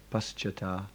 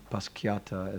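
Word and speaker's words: paścāt: The C in Sanskrit is always pronounced like cha, like in chai, it says, or like in chair, in the word chair, it says here. So therefore also paschata, not paschiata, as some people say.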